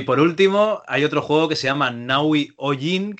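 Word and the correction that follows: Nahui Ollin: que no sé si lo pronuncio bien, o es Olin. Qué manía. La E no se pronuncia, es L. y la ah. H es muda, Naui Olin.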